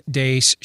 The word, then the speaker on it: Deace: You can like us on Facebook, follow us on Twitter at Steve Dace Show. Last name is spelled D-E-A-C-E.